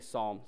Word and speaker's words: psalm: They do say that with like a silent L, like Sinclair Ferguson. Anybody know him? You do. They say Sam. I'll say Psalm.